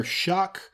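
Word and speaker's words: Shough: A lot of that can be attributed to Tyler Shuck, whose name is not spelled like Shuck, being injured and them having to start the dirty pirate Henry Columbia, who is going to start at Marshall this year.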